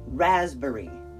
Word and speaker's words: raspberry: R-A-S-P-B-E-R-R-Y. Raspberry. Raspberry. R-A-S-P-B-E-R-R-Y. Raspberry. Raspberry. Now, the emphasis is on the first syllable. Ras. Raspberry. A raspberry has a P in it. Why is there a P in it? I don't know. It's not even pronounced.